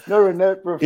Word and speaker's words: norepinephrine: Norepinephrine. – You've got it too. Norepinephrine.